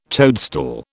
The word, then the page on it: toadstool: British English